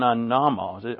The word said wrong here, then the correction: amomos: Amomos. Amomos. The first O is a long O, and the second O is a short O.